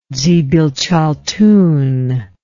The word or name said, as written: dzibilchaltun